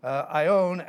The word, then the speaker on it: aion: Ion, actually, A-I-O-N, long O, ion, refers to ages.